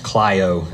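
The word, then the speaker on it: klaio (κλαίω): It, it comes from a, a, Greek word that's pronounced klaio, is the way you say that.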